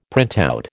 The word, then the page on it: printout: American English